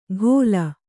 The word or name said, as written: ಘೋಲ